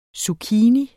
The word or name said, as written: zucchini